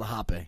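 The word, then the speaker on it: rapé: The guy told us to call the hape. – I think that is the name. – Because, wonder why, because he's probably Brazilian, and the R's, they pronounce H.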